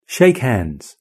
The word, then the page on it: shake hands: British English